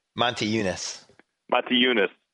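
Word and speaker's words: Motiejūnas: I guess it's Moshe Unish. – Right. – How do you say that? – Monte Unis. Or as center, he's called in Houston, Demo.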